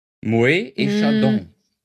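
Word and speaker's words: Moët: Achtung, nur wenn nicht, aber man spricht es Moe aus, nur wenn nicht E Chandon dabei steht. Sollte es dabei stehen, spricht man das T. Moe E Chandon.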